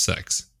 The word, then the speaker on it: CeX: But it actually is pronounced sex.